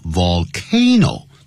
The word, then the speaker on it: volcano: 这个念成 volcanic，可是火山的叫 volcano，这个声音发音就不一样了。 V-O-L-C-A-N-O Vol，你看我只要碰到 L 舌尖就往上翘了， volcano，好，那就是 one volcano， two volcanoes，是这样念的。